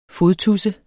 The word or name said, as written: fodtudse